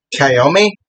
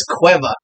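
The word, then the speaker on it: Caoimhe: Okay, so it's C-A-O-I-M-H-E. Okay, so we're all going to think it's K-O-M-E or K-O-M-E-H-E-S, whatever the fuck. It's Cueva. Or Cueva. – Queef? What? Cueva. No offense to my Irish listeners. – So, like, K-W-E-E-V-A.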